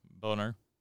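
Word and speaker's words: Buhner: I never use the Beaner or Boehner, however you pronounce it. – Yeah, Boehner.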